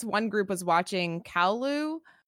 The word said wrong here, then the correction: Caillou: Is that how you say it? Kowloo? – Caillou? – I remember it. Is it Caillou? Okay, Caillou. – Caillou or whatever? – Yes, yeah, that's Caillou. Caillou.